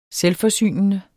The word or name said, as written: selvforsynende